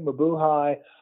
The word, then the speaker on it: Mabuhay: And tell us about those clubs in particular, and I've always struggled with the pronunciation of this, the Mabuhay Gardens. Is that close? – That's pretty close. Mabuhay.